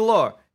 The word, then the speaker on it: Lorre: Peter Lorre.